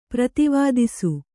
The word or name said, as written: ಪ್ರತಿವಾದಿಸು